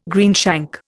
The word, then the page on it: greenshank: Indian